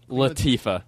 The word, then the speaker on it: Latifah: Latifah.